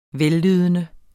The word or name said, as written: vellydende